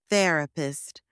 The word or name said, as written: therapist